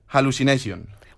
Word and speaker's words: hallucination: Con la H tenemos hallucination.